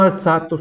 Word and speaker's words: alzato: É, Mi sono alzato subito. Mi sono alzato subito, tá? Lembra da nossa dica? Sempre quando eu tenho um Z depois do L ou depois do N, né? Pronúncia sorda, som de TS.